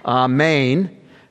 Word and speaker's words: amen: And a little linguistic parenthesis, again, amen, or maybe amen, maybe is how they said it in Hebrew, accent on the second syllable, amen, somewhat connected to other similar words, meaning truth, to believe, to confirm.